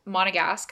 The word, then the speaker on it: Monegasque: Okay, you just said Monegasque. And I have something to say. – Is that wrong? – No, you said it right.